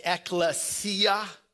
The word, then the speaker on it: ekklesia: It's actually a Greek word. Some of you may know that. Pronounced ekklesia, the Greek word for church.